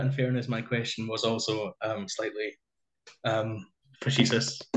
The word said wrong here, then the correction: facetious: no, fascists, fascists. Hmm. Oh God, I can't even pronounce it. Fascist. Fascists. – No, not fascist. No, no, fascists. – Facetious. – Am I saying that right? – Facetious, I think. – Facetious. Yes.